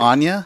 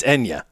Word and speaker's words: Enya: Anya? – No. I don't know that's how you say it. Is it Enya? It's Enya. Isn't it? – If you say so.